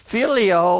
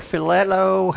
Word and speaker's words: phileo: Filio or phileo, however you want to pronounce it. P-H-I-L-E-O.